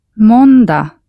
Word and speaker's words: Måndag: Måndag. – All the days of the week end with a G, which is not pronounced.